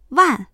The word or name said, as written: wan4